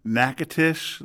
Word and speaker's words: Natchitoches: Natchitoches, Louisiana. Which is not spelled like Natchitoches at all.